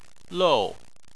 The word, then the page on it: lou: 4